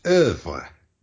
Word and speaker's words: œuvre: I would say œuvre. – Yeah, that's a really common English pronunciation, is œuvre. And that's that vowel that I was talking about at the beginning of this podcast. And we just don't have it in English.